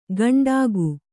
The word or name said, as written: ಗಂಡಾಗು